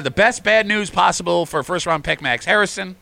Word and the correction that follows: Hairston: Did I say it right, Kimmy? – Hairston.